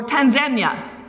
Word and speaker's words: Tanzania: Now, Alpha is from Tanzania, or Tanzania, as I learned is how it is properly pronounced.